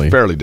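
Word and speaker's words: Fairleigh: Now, Tobin Anderson is the head coach for, is it Farleigh Dickinson or Farleigh Dickinson? Farleigh Dickinson, right? – Farleigh.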